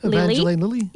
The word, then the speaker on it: Evangeline Lilly: Meanwhile, you've got the story of a former addict, Claire, played by Evan Glean. I can never say her name properly. – Evangeline Lilly.